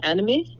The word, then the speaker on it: anomie: And then with your other brother who was talking about the past atrocities, and is anomie how it's said? Anomie? – Anomie, yeah. Anomie.